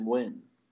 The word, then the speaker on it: Nguyen: And I was surprised they were pronouncing it like that, but they were saying Nguyen.